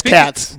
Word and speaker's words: Cats: K-A-T-E. No. – No, my name is spelled both people K-A-T-Z, yeah. But my name is C-A-T-S, just like meow, meow.